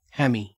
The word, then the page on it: hammy: Australian English